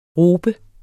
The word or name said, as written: robe